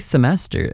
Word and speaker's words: semester: And how do you actually say semester in Slovak? – That's another easy one, actually. Semester. – Oh. – The pronunciation is slightly different, but the word itself is basically the same.